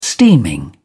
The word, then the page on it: steaming: British English